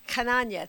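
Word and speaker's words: Chananya: It's Hananya. It's C-H-A-N-A-N-Y-A.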